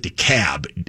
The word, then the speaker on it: DeKalb: It's pronounced, it's written with an L, but they've all pronounced it DeKalb. It's like the city in Illinois, DeKalb, only they call this one DeKalb. D-E capital K-A-L-B.